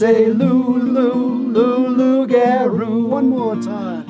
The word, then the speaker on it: loup-garou: It's a loup-garou. – I joined in again. – There you go. – My goodness. Audience participation here from the host. And that is loup-garou. And it's spelled L-O-U-P-G-A-R-O-U. Loup-garou.